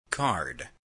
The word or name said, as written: card